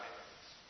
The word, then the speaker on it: Emmaus: Emmaus, right? And you can spell it even. E-M-M-A-U-S.